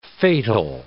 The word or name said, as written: fatal